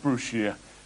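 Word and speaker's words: Brashear: Not spelt Brushear, but pronounced Brushear.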